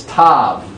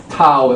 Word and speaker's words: Tav: It's Tav. Or Tau, it might be pronounced. Tau or Tav. Two different pronunciations.